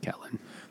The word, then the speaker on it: Catelyn: It, in the show, it's pronounced Catelyn.